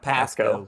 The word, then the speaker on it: Pasco: It's pronounced Pasco, Pasco uh, with an accent.